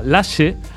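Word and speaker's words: Lashee: El tema se llama Kalimba y el alumno se llama Lashe, pero se escribe L-A-S-H-E-E y su nombre real es Manel, es de Lashe, que Lashe, bueno, es de aquí, ya sabéis. De ahí su nombre, aunque eso, cambia un poquito cómo se escribe.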